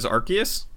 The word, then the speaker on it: Arceus: Okay, did he pronounce it right? – Yeah. – What is Arceus? I thought it was Arceus forever. – I always pronounce it Arceus, but... – I mean, it's pretty close. – The official pronunciation is Arceus, I think.